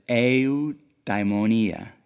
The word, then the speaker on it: eudaimonia: and I'll probably mispronounce it but it's eudaimonia, eudaimonia, E U D A I M O N I A, and it's traditionally translated as happiness.